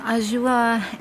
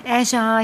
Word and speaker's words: Azure: ja, Azure, Azure, je nachdem.